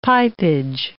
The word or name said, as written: pipeage